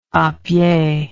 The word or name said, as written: apied